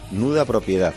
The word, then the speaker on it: nuda propiedad: Sí, nuda propiedad, como nudo, pero en femenino, nuda propiedad.